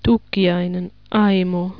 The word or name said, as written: Tukiainen Aimo